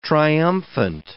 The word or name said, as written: triumphant